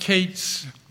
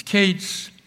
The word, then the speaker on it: qets: And that, that word there, it's pronounced Kate's in uh, it's spelled Q E T S, but it's pronounced A. Uh, e has an A sound. It's Kate's.